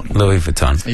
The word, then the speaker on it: Louis Vuitton: Well, thanks to Google, Louis Vuitton.